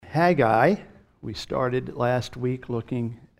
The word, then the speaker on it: Haggai: there's Haggai, and that's the way it's pronounced. Hag, and then I. Haggai.